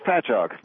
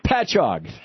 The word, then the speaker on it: Patchogue: It's Patchogue. – Patchogue. Thank you.